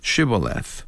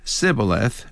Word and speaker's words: Shibboleth: Whenever anyone came to the river, the Gileadites made them say, Shibboleth. Since the Ephraimites were unable to pronounce it correctly, but said Sibboleth instead, they were easy to recognize and kill.